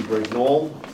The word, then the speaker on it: Brignole: W-I-M-B-F-H-E-I-M-E-R. – Great, okay. And for the appellant? – Attorney Timothy Brignole, and my name is spelled B-R-I-G-N-O-L-E.